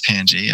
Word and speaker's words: Pangy: It is Pangy, yes, with a soft G, or a hard, or whatever.